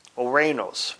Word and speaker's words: ouranos: Now, the word translated air is ouranos. Ouranos, which is spelled O-U-R-A-N-O-S, and it's pronounced ouranos.